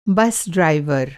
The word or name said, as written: बस ड्राइवर